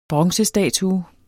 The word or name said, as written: bronzestatue